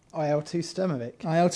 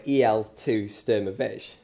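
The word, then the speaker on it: IL-2 Sturmovik: IL2 Sturmovik. – Or as I like to call it, EL2 Sturmovik. – Yeah, it's subject to a lot of bad pronunciation, that game.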